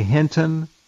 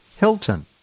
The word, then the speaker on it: Hinton: Jeffrey Hinton was the subject of one video, and this may be it, I'm not sure, but the alleged host, a Motormouth, kept calling him, instead of Hinton, Hilton. Couldn't even pronounce his name right.